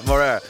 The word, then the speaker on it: Moreira: Limitless, Leslie Vega Morales. No, wait, how you say it? – Morera. – Vega Morera.